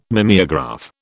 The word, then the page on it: mimeograph: British English